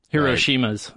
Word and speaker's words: Hiroshima: so this would be like 10 Hiroshimas or Hiroshimas. Right. Is it pronounced Hiroshima? – Well, if you're Japanese, it's Hiroshima.